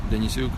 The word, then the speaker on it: Denysyuk: My name's Alexander Denisuk.